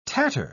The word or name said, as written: tatter